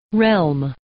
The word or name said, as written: realm